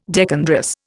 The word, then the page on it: decandrous: Indian